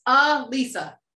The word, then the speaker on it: Alisa: So it's not Alisa. – No, it's not. It's Alisa. Lisa with an A.